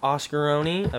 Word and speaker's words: oscarony: I am delighted to have him here, a man I'm sure you're all familiar with, user oscarony, I believe. Is that how you pronounce it, oscarony? – You can just say Oscar O-N-Y. – Oscar O-N-Y. – When it sounds like Oni, it kind of...